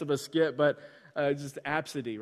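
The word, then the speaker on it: Abcde: And it's like actually pronounced Absidy, but it's A, B, C, D, E.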